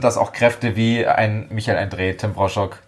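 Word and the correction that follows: Broshog: Tim Brossock, so wird er ja richtig ausgesprochen, ein äh, Thomas Kotzian.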